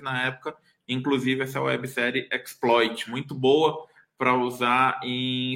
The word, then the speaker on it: xploit: Quem não quem não, quem está no podcast, né, não, não tá vendo o link aqui, é só digitar trailer xploit, x-p-l-o-i-t, xploit, que se pronuncia exploit, né?